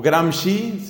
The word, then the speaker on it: Gramsci: Hay pensadores, entre los cuales dos que hay que conocer son un italiano, cofundador del Partido Comunista en ese país, Antonio Gramsci, o Gramsci, se escribe G-R-A-M-S-C-I, y una mujer, una francesa llamada Simone de Beauvoir.